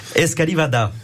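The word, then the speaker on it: escalivada: Ensuite, nous avons les fameuses escalivadas. C'est bien prononcé. bien prononcé.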